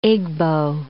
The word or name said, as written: Igbo